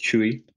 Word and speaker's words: Chuy: So for those of you who don't know, my name is Chuy. It's spelled C-H-U-Y.